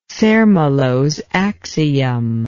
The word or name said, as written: zermelos axiom